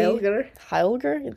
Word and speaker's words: heilagr: So Helga comes from, I don't know how to say it, but it's H-E-L-G-E. So that's Old Norse, which is H-E-I-L-A-G-R. But that means holy. – Heilger? – Thank you.